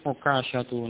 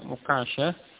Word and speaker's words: Ukasha: Huruf kaf pada nama Ukasha ini tidak tasdid. Sehingga cukup diucapkan Ukasha atau Ukasha. Kemudian apa pendapat yang lain atau ucapan yang lain, nama Ukasha di situ kafnya dengan tasdid, kafnya itu dengan tasdid sehingga diucapkan apa Ukasha, ya Ukasha, ya kafnya dengan tasdid ya.